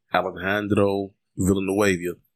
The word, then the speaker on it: Alejandro Villanueva: However, they could still bring back Alejandro Villanueva.